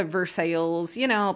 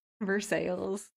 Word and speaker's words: Versailles: Down in like Cairo, basically Versailles, places that are French but pronounced wrong. Versailles.